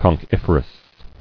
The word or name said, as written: Conchiferous